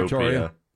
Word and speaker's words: Pretoria: And I might be saying that wrong. – Fake no, Pretoria. – You're saying it right.